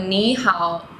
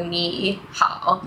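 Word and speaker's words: 你好: So ni hao, both are third tones. So ni hao.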